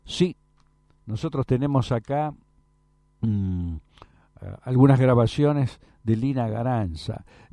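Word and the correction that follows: Garanča: Y Garancha, mejor dicho, así se pronuncia.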